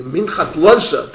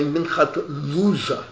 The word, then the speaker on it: Minchat Lazer: And he quotes the Munkacha, and remember the Munkacha, Reb Lazer Shapiro, the Minchat Lazer, that's the way I heard it pronounced. The real Galicianos say Minchat Loser, Loser. For the Americans, it's not so good to say Loser.